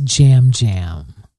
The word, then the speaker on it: Yam Yam: It's spelled Y-A-M. Y-A-M. – Yeah, come on. – It's called... – Please. – Spelled Y-A-M, Y-A-M, but it's pronounced Jam Jam.